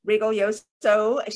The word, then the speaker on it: Rigoglioso: It's very important to pronounce her last name properly. We have learned it often gets mispronounced. So Reguilloso, she is an expert in the feminine,